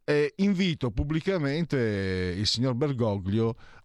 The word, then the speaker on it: Bergoglio: Signor Bergoglio, perché si pronuncia Bergoglio alla... perché è castigliano, non all'italiana Bergoglio, anche se lui è di origine piemontese.